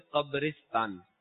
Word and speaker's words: قبرستان: اچھا، اس کا صحیح تلفظ ہے قبرستان، قبرستان نہیں قبرستان.